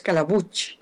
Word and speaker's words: Calabuch: Es muy fácil de pronunciar, es Calabuch, como si tuviera una che al final.